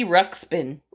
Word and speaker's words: Ruxpin: We joke with the dog because his name is Ted, and we call him Teddy Rupskin. – No, Teddy Rupskin. – Because he rubs his skin. He's so gross. – Teddy Ruxpin. – I can't say. I've been saying it wrong for too many years.